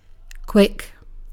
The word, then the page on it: quick: /kwɪk/